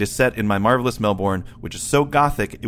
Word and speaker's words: Melbourne: It's pronounced Melbourne, by the way. Get a mention. I think we pronounce it all kinds of stupid ways. – Okay. – But yeah, Melbourne's the way that you're supposed to say it.